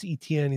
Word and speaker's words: Etienne: Let's talk about Travis Etienne.